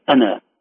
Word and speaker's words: أنا: قل إن كان للرحمن ولد فأنا أول العابدين، لاحظوا أحبتي إن كان أمامكم مصاحف فأنا أول العابدين، فأنا كلمة أنا فيها ألف لكن ألف لا تنطق،